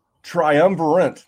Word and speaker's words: triumvirate: We can we can put you and me and Neil in there, and we'll like draft as like a triumphant, like a try like whatever a three is. – Triumvirate. – There you go. I was on the right track, wrong pronunciation.